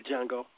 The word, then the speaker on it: Django: First, what is the pronunciation of that movie? Django? – Yeah, Django.